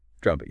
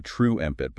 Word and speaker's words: trumpet: Trumpet, yeah. Where the U sound is critical. It's not true empit, but trumpet, like the word jump, short yeah.